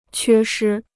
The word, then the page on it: 缺失: quē shī